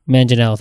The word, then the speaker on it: Manganiello: Manganiello, thank you.